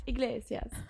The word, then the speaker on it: Iglesias: By the way, Enrique Iglesias is, I don't know how to pronounce it right. – Iglesias.